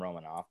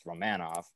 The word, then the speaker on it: Romanov: And then I hate the way they pronounce his name, but apparently it is pronounced as Romanov. I want to call him Romanov, but it's Romanov.